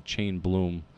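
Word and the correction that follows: Chaim Bloom: Heimblum. – Yeah. Heimblum. Sorry. Chain... Why do you say Heimblum? – That's how you say it. C-H-A-I-M. You say it Heim.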